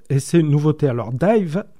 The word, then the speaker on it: DIIV: Et ça tombe bien, en anglais, les lettres D-I-I-V, ça se prononce aussi Dive.